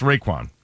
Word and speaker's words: Raekwon: No, it was Method Man, Inspector Deck, and I forgot how to pronounce his name. Anyway. – Raekwon? – Yes, Raekwon. That's correct.